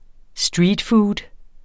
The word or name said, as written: streetfood